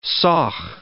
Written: sauch

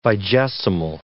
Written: vigesimal

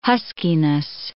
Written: huskiness

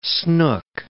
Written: snook

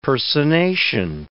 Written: personation

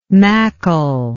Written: mackle

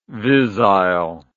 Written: visile

